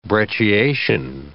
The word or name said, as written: brecciation